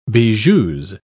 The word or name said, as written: bijoux